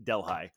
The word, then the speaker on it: Delhi: It looks like Delhi, but the the locals pronounce it Delhi.